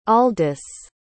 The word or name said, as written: aldys